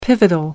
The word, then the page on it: pivotal: American English